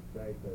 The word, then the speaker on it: Zicha: You know, it's spelled out Zyza. Z-I-C-H-A. Zyza.